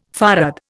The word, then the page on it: farad: Indian